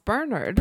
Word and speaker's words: Bernard: Yes, pronounced Bernard. – Pronounced Bernard, because we're in Britain, baby.